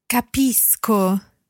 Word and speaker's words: capisco: Capisco. – Now, there we've got ISC coming after the stem, cap, and before the ending, o. So if C goes straight into o, then it's a hard C sound.